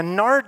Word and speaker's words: nard: Now, nard doesn't sound very good to us, right? It rhymes with lard.